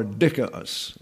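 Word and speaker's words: dikaios (δίκαιος): Or dikaios is really the way it's pronounced.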